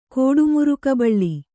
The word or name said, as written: ಕೋಡುಮುರುಕಬಳ್ಳಿ